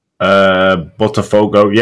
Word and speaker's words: Botafogo: Navarro currently playing for, James, am I saying it right, Botafogo? – Botafogo, yeah, Botafogo.